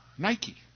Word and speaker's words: nike: That shouldn't mean anything, but you would spell that N-I-K-E. The shoes are named after that word for overcome or victory. Nike in Greek, nike.